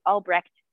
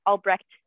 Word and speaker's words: Albrecht: and I pronounce it Albrecht, but every time I go to Germany, Albrecht, and I pronounce the T at the end, but I don't even pronounce it like it's supposed to be pronounced.